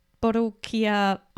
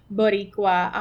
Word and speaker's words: Boricua: At the time of the recording, you just finished up the Borukia Entrepreneur Fest. Please pronounce this for us. What is it called? Boricua Entrepreneur Fest. Yeah. – So close.